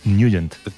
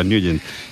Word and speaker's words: Nugent: Siempre lo hemos llamado en el barrio Tednagent o Tednagent. – Claro, ¿no? Yo era de Tednagent. – Pero la pronunciación correcta es Nugent. – Te fuiste a Washington y descubriste que era Nugent.